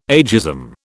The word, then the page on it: ageism: British English